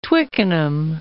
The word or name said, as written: Twickenham